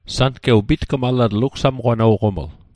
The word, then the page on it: pitgmalatl: Mi'gmaq